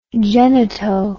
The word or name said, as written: genito